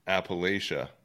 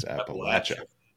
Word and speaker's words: Appalachia: Right, we actually learned, before that we thought it was Appalachia, but we learned when we talked to the Davison Brothers band that it's Appalachia.